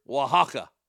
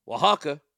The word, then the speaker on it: Oaxaca: Oaxaca. I don't know why I don't spell it with a W. Oaxaca, considered one of the safer states in Mexico, 60 miles west of Puerto Escondido in the hamlet of La Isla.